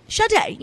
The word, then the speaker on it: Sade: He was just like, Sade, you know.